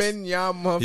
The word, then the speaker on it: Wembanyama: Victor Wembanyama. – Oh, I can't even pronounce that last name. Victor, long man from France. – Wembanyama.